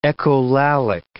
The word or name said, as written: echolalic